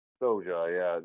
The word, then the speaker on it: SOJA: Soja? – Soja, yeah.